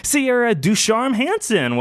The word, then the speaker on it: Sierra DuCharme-Hansen: Please welcome her first time to The Gory Days, but maybe not her first time to podcasting, Sierra DuCharme-Hansen.